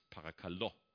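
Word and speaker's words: parakalo (παρακαλώ): Das gleiche Wort parakaleo, mittlerweile im Griechischen wird es parakalo ausgesprochen.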